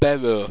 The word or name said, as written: bero